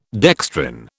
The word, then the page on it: dextrin: British English